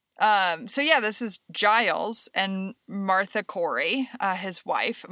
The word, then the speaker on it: Giles: Is this the story of Giles Corey? – Yes. Is that how you pronounce it, yes. Giles? – Yes. – Okay. I was reading it as Giel.